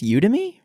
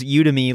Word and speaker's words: Udemy: So let's dig into Udemy, as you said, although I think it's Udemy, right? Have you been saying it wrong your whole life? – I always say Udemy. – Well, see, in my head, it was Udemy, like you to me, right?